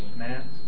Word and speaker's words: Nantes: Nantes, was issued in 1598, which granted religious freedom to the Huguenots.